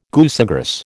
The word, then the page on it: goosegrass: American English